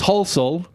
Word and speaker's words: Halsall: And I thought it was down to people not knowing how to pronounce my last name, which is Halsall. But being in America for so long now, I've had all sorts of people try and pronounce it and pronounce it wrong. I've had Hatzel, Hissel, Hossel, Wholesale, another few words which I can't say.